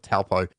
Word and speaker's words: Taupo: Now, I don't think I've pronounced it right. I know that the locals say it completely different, but us Aussies call it Taupo.